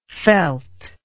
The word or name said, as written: felty